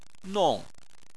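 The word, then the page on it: nong: tone 4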